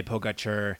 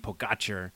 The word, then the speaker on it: Pogačar: So as we saw at Puy-Marie, this race has really separated into a two-man battle between Tadej Pogačar and Tadej Pogačar. If someone says you've been putting the emphasis on the wrong syllable, so Tadej Pogačar and Primož Roglič.